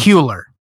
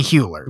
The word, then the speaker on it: Hüller: There's umlauts over the U. – It's Hüller. – Yeah. It'd be Hewler, yep.